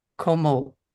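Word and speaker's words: 木漏れ日: Takuma, the word, and if please help me if I say it wrong, komorebi. – Yes.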